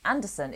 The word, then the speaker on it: Anderssen: Anderssen is not spelt like Anderson. It's spelt like Andersen, like a Swedish person with two S's and an E-N at the end.